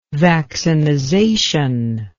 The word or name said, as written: vaccinization